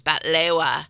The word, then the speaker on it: batlewa: The other thing, too, is that baklava is Turkish or Greek, but in Lebanon, it's batlewa. So Let me try. Baklewa. – With a T, batlewa. It sounds like a T. – Oh, B-A-T?